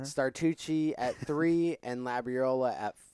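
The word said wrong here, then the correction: Starocci: Wait, what did you say his name was? – Startucci? – Staracci. – Staracci.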